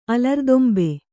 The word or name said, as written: ಅಲರ್ದೊಂಬೆ